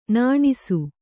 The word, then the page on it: ನುಣ್ಣಿಸು: nuṇṇisu